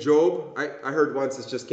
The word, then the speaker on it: Job: It's pronounced Job.